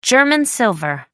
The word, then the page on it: German silver: American English